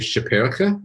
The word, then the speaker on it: Schipperke: Is, first of all, it's a horrible American pronunciation of what should be, I believe, Schipperke, which is for a little skipper.